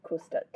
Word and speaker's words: Kusta: um, there's a fiber artist called is it Custer. I need to. I'll have to spell his name out No worries. because um, I can never um, remember how to pronounce it. Yeah. So his name is Kusta.